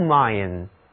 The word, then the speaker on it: mine: mine 这是指矿山、金属矿，比如 a gold mine， a gold mine 就是金矿，那么 a coal mine 那就是煤矿了。这里的字母 I 也读作 I mine，在矿井中干活的人我们叫他们矿工，这就是 miner， miner 其中的 er 就是表示人的名词后缀。